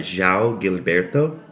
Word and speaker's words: João Gilberto: This is by a guy, João Gilberto. He's kind of the godfather of bossa nova. – Say his name one more time. – João Gilberto.